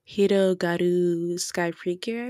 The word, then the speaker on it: Hirogaru Sky Precure: I believe it's pronounced Hirogaru Sky Precure, I believe.